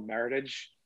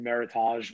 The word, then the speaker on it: Meritage: We call it, in America, they call it Meritage. A lot of people think it's pronounced Meritage because it sounds French, but it was a term that American growers used. It was a combination of merit and heritage because they can't call it Bordeaux, or Bordeaux growers get pissed off.